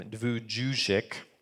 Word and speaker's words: Vujicic: Vujicic.